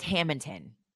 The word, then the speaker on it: Hammonton: And it's Hamilton. That's how you say it.